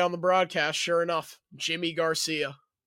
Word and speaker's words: Yimi: Yeah, oh yeah, Jimmy. – I thought it was Yimi. – That's how it's spelled. Pronounced Jimmy.